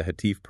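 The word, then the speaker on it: Hatif (هاتف): Alima also notes that this category includes those eerie, deeply unsettling experiences found in many global traditions of the Hatif, pronounced H-A-T-I-F, the unseen caller, a voice that is heard clearly, delivering a coherent message, but with absolutely no speaker visible to the eye.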